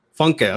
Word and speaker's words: Funke: Funke.